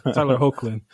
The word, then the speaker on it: Hoechlin: I mispronounced it the first time I said it. It's like Tyler Hoechlin, and I was like, my bad. Tyler Hoechlin.